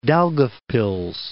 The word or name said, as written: Daugavpils